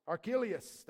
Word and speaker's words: Archelaus: But when he heard that Archelaus, how do you say that one? Somebody help me. Archelaus, thank you, was ruling over Judea in place of his father, Herod he was afraid to go there.